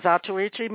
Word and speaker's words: Zatoichi: and it was in a Zatoichi movie. Am I pronouncing that right? – I think it's a Zatoichi. – Oh, Zatoichi movie.